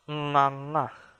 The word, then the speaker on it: nganga: Nganga, nganga. So this is an NG sound. The beginning of the syllable is actually NG. Nganga. So this is where a lot of English speakers stumble with because of the NG sound starting the word or the syllable.